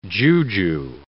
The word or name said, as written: juju